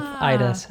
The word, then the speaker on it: Ida: See, I pronounced it Ada because obviously in we have the A and the I that makes the A sound, but Ida, I guess, is the correct way to pronounce it.